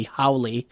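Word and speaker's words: Hawley: Not spelled like Holly, but I could pronounce it Teddy Howley. – Is it H-A-W? – Yes.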